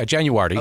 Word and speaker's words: JanuARTy: Yeah, JanuARTy. Okay. So think January, but with a T, and you kind of start your art experience there. So JanuARTy.